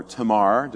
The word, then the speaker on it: Tamar: Absalom would have a sister named Tamar, or Tamar, depending on which emphasis you put on which syllable. But let's say Tamar for Tamar. Tamar. Let's go with Tamar.